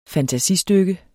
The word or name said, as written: fantasistykke